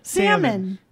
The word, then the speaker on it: salmon: Salmon. There's no L. – Salmon. – It's S-A-L-M-O-N. Yeah, it's salmon. – But you don't. – Oh, there is an L, huh? – Yeah, you don't fucking pronounce the L.